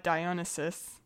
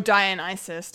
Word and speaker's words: Dionysus: Just for some reason, it made me think of the Greek god Dionysus, or Dionysus, depending on your pronunciation.